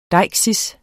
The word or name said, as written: deiksis